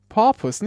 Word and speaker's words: porpoise: Porpoise, nicht Purpose, Porpoise.